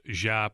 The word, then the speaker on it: Diop: The last name is officially pronounced Jop, but he said, hey, a lot of people say Diop, just like Bobby Marks said in the interview. He's accepted that, but the official pronunciation is Keita Bates-Diop.